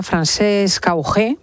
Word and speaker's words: Francesc Augé: Tenemos con nosotros a Francesc Augé, espero decir bien el nombre, que es un caballero de 56 años, que ha tenido 4 ictus y 2 infartos, ¿es así, Bea? ¿Lo digo bien? – Ah, sí, perfectamente.